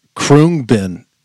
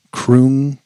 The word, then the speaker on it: Khruangbin: So Google says pronounced Khruangbin. Khruangbin. – Khruangbin.